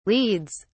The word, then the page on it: leads: British English